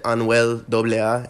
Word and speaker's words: Anuel AA: There's one thing that I think we're kind of all standing in solidarity against, which is some statements that Anuel AA, did I say that right? – Yeah.